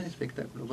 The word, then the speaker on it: espectáculo: Lo dije bien, espectáculo. Porque sí. Al principio cuando decía espectáculo y decía muy mal. – Vuelve la acentuación.